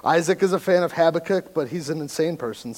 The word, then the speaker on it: Habakkuk: First thing is it's pronounced Habakkuk, all right, not Habakkuk.